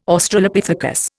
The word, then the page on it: Australopithecus: Indian